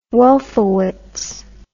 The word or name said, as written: wolfowitz